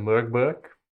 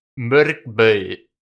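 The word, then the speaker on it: Mörk Borg: Volgens mij is het iets in de trant van Murkbeu. Als je het echt op zijn Zweeds uitspreekt.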